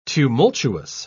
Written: tumultuous

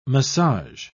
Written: massage

